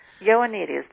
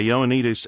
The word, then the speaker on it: Ioannidis: Your article highlights the work of a specific physician, and I'll probably mispronounce his last name, Dr. John Ioannidis. – Ioannidis, very close. – Okay, Ioannidis.